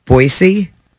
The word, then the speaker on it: Boise: Boise.